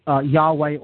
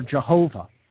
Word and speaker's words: Yahweh: Yod, He, Vav, He, which we derive when we transliterate those characters, Hebrew characters, into English, we get YHVH, which then we would pronounce Yahweh or Jehovah.